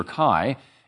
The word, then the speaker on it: chi: Uh, the X was the Greek letter pronounced ki or chi.